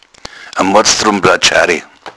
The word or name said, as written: blatschare